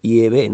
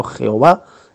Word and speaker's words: Yahvé: Es decir, el Dios Padre, el Dios Supremo tiene un nombre, que parece ser que la pronunciación más correcta es Yahvé, en hebreo arcaico. Yahvé, no Jehová, sino Yahvé.